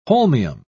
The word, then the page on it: holmium: British English